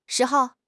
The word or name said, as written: shi2 hou5